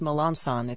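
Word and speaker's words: Melancon: Charlie, it's pronounced Melanson.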